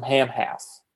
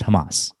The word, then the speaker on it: Hamas: And that's when things get bad to worse, because then there's a threat from Hamas. – It's pronounced Hamas. – Oh, get out of here, really? I've only seen it on paper, I never heard it pronounced before.